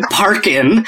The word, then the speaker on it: Parkin: Kane Dingle and Stephen Parkin. And it's spelled P-A-R-K-I-N. Like, yeah. – like a country song about parking.